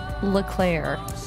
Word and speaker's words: Leclerc: Leclerc.